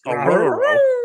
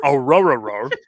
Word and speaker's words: Aurora: It's almost like Scooby-Doo a little bit. It's Aurora. Aurora. That's basically how it is.